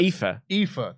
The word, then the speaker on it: Aoife: How do you pronounce that? – Aoife.